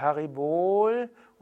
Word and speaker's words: Haribol: O ist auch im Sanskrit nie kurz ausgesprochen, sag also nicht Om oder Haribol, oder Hari Om oder Bolo, sondern das O ist immer lang. Also es ist Om und Haribol und Bolo. Das O ist lang.